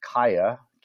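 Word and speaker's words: Qaya: And in conjunction with this, it was very interesting to see Google announce via its Area 120 incubator launching a service called, we believe it's pronounced Kaya, Q-A-Y-A, a new service that allows the creation of very simple storefronts that can be embedded into an Instagram profile.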